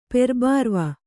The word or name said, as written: ಪೆರ್ಬಾರವ